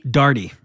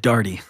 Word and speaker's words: Daugherty: Daugherty. – Daugherty. Okay. – Just like party, but Daugherty.